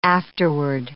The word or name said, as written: afterward